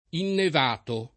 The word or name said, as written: innevato